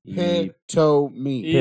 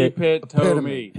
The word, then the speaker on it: epitome: Three syllables. Pit-to-me. – Epitome. – Pit to me. – Epitome. – You're leaving the E off.